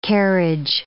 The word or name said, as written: carriage